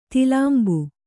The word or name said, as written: ತಿಲಾಂಬು